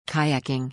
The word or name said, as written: Kayaking